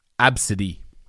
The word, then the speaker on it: Abcde: And on names, my aunt had a student called A, B, C, D, E, pronounced Absidy.